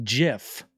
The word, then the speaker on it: GIF: I think the creator has gone on record to say it is pronounced JIF. But I've been calling it GIF for 30 fucking years.